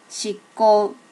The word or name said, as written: shikko